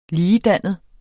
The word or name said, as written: ligedannet